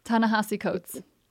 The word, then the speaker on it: Ta-Nehisi Coates: And I never say his name right. Ta-Nehisi Coates? – Ta-Nehisi Coates. – Thank you very much.